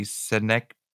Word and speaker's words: Synecdoche: Oh, Syndicoach? How do you pronounce that? Something. – Synecdoche. – Is it Synecdoche, New York? – Yeah.